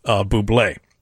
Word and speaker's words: Bublé: That was the entire thing, even though his last name is pronounced Bublé.